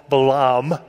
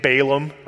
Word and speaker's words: Balaam: Sometimes we pronounce his name Balaam, right, if we're going to be American about it. But in Hebrew, it's Balaam.